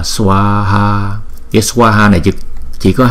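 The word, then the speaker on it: svāhā: Chúng ta phải đọc là swaha, swaha. Chữ swa là một nhịp, là một âm. Chữ swa là một âm và chữ ha là một âm. Tổng cộng là hai âm là swaha.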